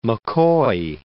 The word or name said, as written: McCoy